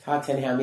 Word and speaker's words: Tottenham: Tottenham. – Yes. That's apparently how you say it. – Yeah. – Tottenham, yes.